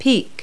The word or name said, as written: peak